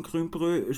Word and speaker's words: Grümbrö: Wie spricht man Grümbrö? Spreche ich das richtig aus und was bedeutet ja. Das genau? – Genau. Ist richtig ausgesprochen.